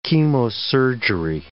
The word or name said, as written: chemosurgery